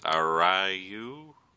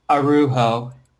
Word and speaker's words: Araujo: And this is Andre Lima, forgive me for butchering this, Arayo? – I think it's Arujo. – Ah, that's probably better.